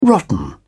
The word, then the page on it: rotten: British English